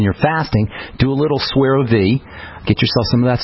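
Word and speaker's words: Suerovie: S is in Sam, U-E-R-O-V-I-E, Swerve V.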